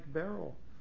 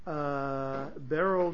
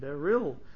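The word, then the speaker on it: Beryl (בערל): The way I just pronounced it, you would spell it Beis, Ayin, Reish, Lamed. Other people I hear tell spell it Beryl, because they hear the yud as a separate sound, beril, instead of beryl. It depends where you put the accent, at the beginning or at the end.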